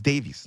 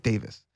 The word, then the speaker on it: Davies: It's Davies, not Davis. I don't know why they think the E is silent.